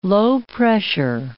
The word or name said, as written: low-pressure